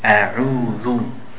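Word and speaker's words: A'udhu (أعوذ): And whenever you have the dhamma on any letter in Arabic, you have to circle the lips. A'udhu. See how my lips, I'm making a circle. That way you pronounce it properly.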